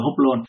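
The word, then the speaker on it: hoplon (ὅπλον): Chữ đồ dùng vũ khí này trong tiếng Hy Lạp là hoplon, đọc là hoplon, được nhắc đến 6 lần đều nói về ý nghĩa của chữ là vũ khí.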